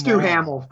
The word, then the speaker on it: Duhamel: So Dumal. – It's Duhamel. Fuck him. Duhamel. It's Duhamel now.